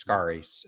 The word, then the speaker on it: Scarry: And you end up writing about a quote that Elaine, I think her name is pronounced Scarry, S-C-A-R-R-Y.